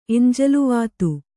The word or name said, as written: ಎಂಜಲ್ವಾತು